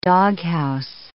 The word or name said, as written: doghouse